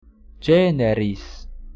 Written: generis

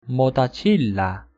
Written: motacilla

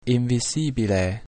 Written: invisibile

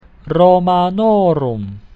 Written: Romanorum

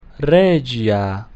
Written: regia

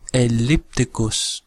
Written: ellipticus

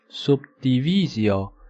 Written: subdivisio

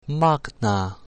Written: magna

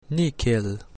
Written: nihil

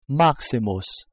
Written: maximus

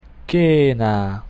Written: cena

